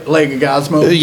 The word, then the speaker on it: Leguizamo: Leguizamo? – Yeah, whatever.